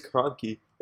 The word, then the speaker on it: Kroenke: It's Kroenke.